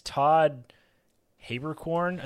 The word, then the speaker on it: Haberkorn: It's Todd Haberkorn, I think is how you say it.